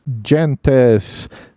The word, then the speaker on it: Gentes: All right, next game I played was Gentes. I'm pretty sure that's the correct Latin pronunciation.